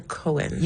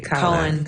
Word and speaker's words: Cowan: Is it Cowan or Cohen? – You actually got it right. – Cowan.